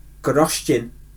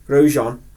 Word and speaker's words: Grosjean: At which track did Roman Grosjean, did you sleep in him? – Grosjean have a spectacular crash.